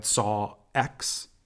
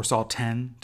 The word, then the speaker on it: Saw X: Like last week, we had Saw X or Saw 10, depending on how you want to pronounce it. I don't count Jigsaw and Spiral as Saw movies, so I say X, although it is technically the 10th film with those two, which I loved.